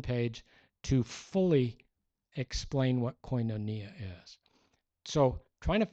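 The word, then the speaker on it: koinonia (κοινωνία): The Greek word is the word koinonia. It's spelled K-O-I-N-O-N-I-A.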